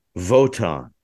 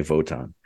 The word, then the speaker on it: Wotan: He talks about this super spy, this spy named Votan, which is spelled with a W, but because he's German, they say it with a V.